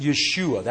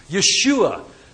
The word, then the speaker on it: Yeshua: They would have pronounced it Yeshua. That was the Hebrew. Yeshua, Son of David.